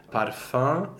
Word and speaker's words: parfum: Another pronunciation point, we don't often get the O-E nasal, but here we actually have it in a rhyme in the first stanza. – Yes, parfum and défunt.